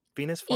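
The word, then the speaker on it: Venus Flytrap: And today, we're joined by none other than Venus Flyap. Is that right? Venus Flyap? – Yeah, the TR is silent.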